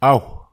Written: ahu

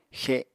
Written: xe7

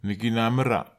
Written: nekinamera

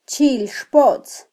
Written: chilxpotz

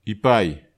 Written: ipai

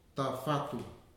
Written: Tafatu

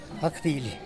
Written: gakdily

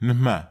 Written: nehma

